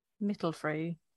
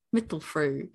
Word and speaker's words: Mittelfrüh: Hallertau, Mittelfrüh. – Mittelfrüh.